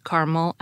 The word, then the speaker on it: caramel: Gardner's Modern American Usage acknowledges three pronunciations for the word, saying caramel is best, caramel is second best, and caramel is worst, although he doesn't give a reason for his ranking.